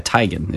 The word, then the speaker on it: Teigen: Chrissy Teigen, as you called her, actually finally admitted that we've been pronouncing her name wrong all this time. It's Teigen. – It's a Norway, right? Or something? But she said she still wants to be called Teigen. I saw some interview. – Right. – Yeah. But yeah, Teigen is the proper pronunciation. All these years are doing it wrong.